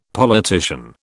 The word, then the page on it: politician: British English